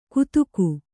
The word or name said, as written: ಕುತುಕು